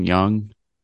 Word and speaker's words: Jung: Young. It's spelled J-U-N-G, but it's pronounced Young.